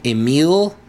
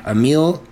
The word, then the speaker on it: Emiel: I don't even know if I'm going to say this correctly. Emile Cleaver. E-M-I-E-L Cleaver. Emile Cleaver.